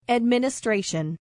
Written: administration

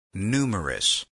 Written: numerous

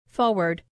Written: forward

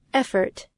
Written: effort

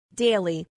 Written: daily

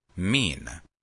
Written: mean

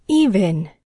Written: even

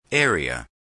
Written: area

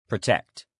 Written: protect

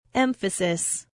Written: emphasis

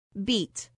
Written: beat

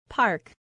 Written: park

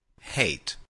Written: hate

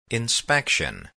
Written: inspection

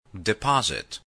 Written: deposit

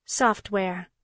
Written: software

